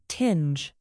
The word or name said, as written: tinge